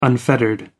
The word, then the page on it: unfettered: American English